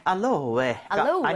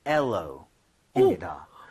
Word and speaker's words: aloe: 알로에가 아니라 발음은 엘로입니다. – 뭐요? – 에는 아예 발음이 없고요.